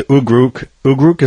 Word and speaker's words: ugruk: yesterday we finally get an ukuk. It's a bearded seal that our people use year-round to sustain us. – The ugruk, is that how you say it? – Yes.